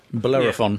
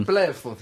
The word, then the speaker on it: Bellerophon: Bellerophon. – Bellerophon, thank you.